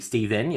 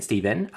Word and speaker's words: Steven: Look, personally, my name's Steve or Steven. I used to be really pedantic when I was a kid. It always used to be Steven. You had to pronounce the N at the end, Steven.